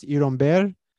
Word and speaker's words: Iron Beer: Which in Cuba would be pronounced Irumber.